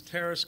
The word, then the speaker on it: Taras: My name is Terrace Cuckman. I'm a resident of Concord, and my name is spelled T-A-R-A-S, last name Cuckman, K-U-C-M-A-N.